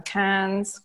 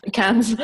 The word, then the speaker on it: Cairns: We've done Sydney, we did the Great Ocean Road, we've done Cairns, I never say it right. – Cairns.